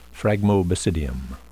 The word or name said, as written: phragmobasidium